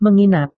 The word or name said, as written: menginap